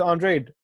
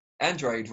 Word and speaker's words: Andrade: Andrade rather than Andrade. – Oh, Andrade. Andrade. Yeah. I call him both, bro. I've said both. What is it? – Usually, with a Mexican fighter's got that surname, it is Andrade, but for him, it is Andrade. Like, I've interviewed him and he said specifically, that's how you pronounce my name.